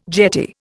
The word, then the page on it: jetty: Indian